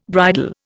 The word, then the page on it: bridal: Indian